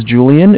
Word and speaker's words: Julian: My name is Julian. It's J-U-L-I-A-N. – Uh-huh. – And I'm calling from Bank of Cardiff. It's spelled C-A-R, D as in David, I-F-F as in Frank Frank.